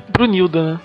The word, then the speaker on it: Brunhilda: Brunhild, não sei como se pronuncia. – Brunhilda.